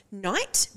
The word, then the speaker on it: knight: So silent K's. Knight, but like a knight in shining armor. K-N-I-G-H. – Fuck. T. Knight in shining. Knowledge? – Knowledge. – K-N-O.